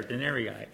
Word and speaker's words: denarii: I landed it right there, denarii.